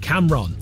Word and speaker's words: Camron: Cameron Jones, and I've not said it wrong, it's spelt C-A-M-R-O-N, Cameron.